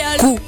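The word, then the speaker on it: cou: Y a ver, el cuello se dice en francés cu, tal cual, cu.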